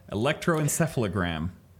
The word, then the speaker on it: electroencephalogram: Yeah, electroencephalogram.